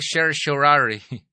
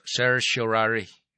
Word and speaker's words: certiorari: Sama gud aning certiorari. Ang kining certiorari mao na sakto nga pag-pronounce certiorari. Oh. Dili certiorari, certiorari. Mao sakto nga pag-pronounce ana.